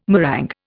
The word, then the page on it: meringue: Indian